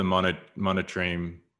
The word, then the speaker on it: monotreme: Yeah, so they're part of the monotreme. How do you pronounce that? – Monotreme, yeah.